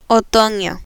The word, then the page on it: otono: Spanish